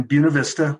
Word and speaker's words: Buena Vista: I grew up in Buena Vista, which is the correct way to pronounce that. My family homesteaded there back in the 1880s, so we have a lot of history there.